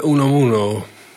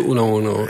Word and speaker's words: Unamuno: Dat is Miguel de Unamuno. – En Miguel de, Unamuno. En Ik ben zelf ook een, niet eh, een, eh, een, het Spaanse machtig. Maar eh, dat is ongeveer de uitspraak, ja.